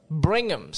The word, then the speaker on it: Brigham: So we stopped once in Bingham City. – Brigham? – Brigham City, sorry. – Brigham. – Brigham. – Brigham. – I can't pronounce that. – Yeah, it's Brigham.